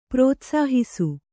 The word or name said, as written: ಪ್ರೋತ್ಸಾಹಿಸು